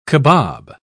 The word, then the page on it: cabob: British English